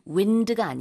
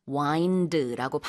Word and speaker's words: wind: Spelling이 W-I-N-D지만 wind가 아니라 wind라고 반드시 발음을 해주셔야 됩니다.